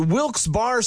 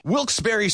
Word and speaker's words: Wilkes-Barre: And you're listening to Wilkes Barre Scranton's 97.9X. – Now, I had heard that it was supposed to be pronounced Wilkes-Barre. – Wilkes-Barre Scranton's 97.9X. The only way to pronounce Wilkes-Barre.